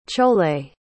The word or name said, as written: chole